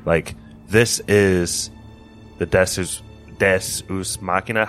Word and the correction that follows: Deus ex machina: How do you say that, Pat? – Deus Ex Machina.